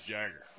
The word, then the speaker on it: Jagar: J-A-G-A-R. – I'd say for a guy, it's Jagger.